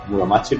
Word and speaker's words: Muromachi: Eh, Wuwang está basado en la época, bueno, como ya hemos comentado, en la época Muromachi puede ser, se pronuncia así.